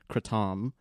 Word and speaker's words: kratom: I think Hamilton Morris calls it kratom. It would be the correct way to say it in Thailand, yeah.